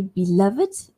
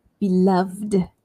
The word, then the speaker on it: beloved: The word B-E-L-O-V-E-D, I can say beloved or beloved. So in some dictionaries, it says that you can say it with a D sound without the extra syllable.